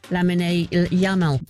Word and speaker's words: Lamine Yamal: And hopefully she'll pronounce Yamin Yamal again. – Lamine Yamal.